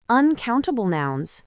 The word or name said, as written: uncountable nouns